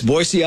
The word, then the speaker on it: Boise: How do you pronounce it? – Yeah, it's Boise, Idaho. – Wait, what are you saying, Boise? – Boise.